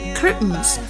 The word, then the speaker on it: curtains: 好，下一个窗帘儿 curtains，我们可以听到音美音里面有一些 t 是不发音的，然后直接把它吞掉，读成 curtains。